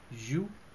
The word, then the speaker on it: jeu: So it's je and not joue. Je is games.